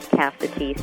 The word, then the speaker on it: Cassity: My last name is spelled Cassity, C-A-S-S-I-T-Y.